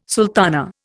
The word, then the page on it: sultana: Indian